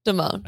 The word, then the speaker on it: Damon: And she said she pronounced her last name as, it's Kia Damon. – Damon. – I think it was Damon.